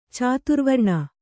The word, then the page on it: ಚತುರ್ವರ್ಣ: caturvarṇa